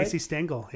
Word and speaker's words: Stengel: Casey Stengel.